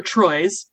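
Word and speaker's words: Troi: In a crewman's quarters, Counselor Troyes, pronounced like the French three, is speaking to a child named Clara, who is talking about making purple omelets using grape juice.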